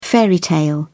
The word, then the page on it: fairy tale: British English